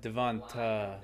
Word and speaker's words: Devonta: It's a long A. You know, like phonics. Like Devonta.